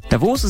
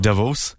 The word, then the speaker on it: Davos: Davos. – Right. Okay. So Christian has been on to say, we stress the O in Davos.